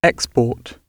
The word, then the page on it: export: British English